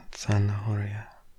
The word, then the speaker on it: zanahoria: The Z at the beginning is pronounced with a th, zanahoria. So we'll move on to the next word. Pepino. Pepino. El pepino. Pepino. E-P-I-N-O.